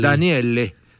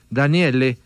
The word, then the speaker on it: Daniele: Pues entonces muy chimbo ese nombre, sí, que se pronuncia igual. Yo pensé que era como Daniel. – Daniele. Daniele.